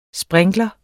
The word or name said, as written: sprinkler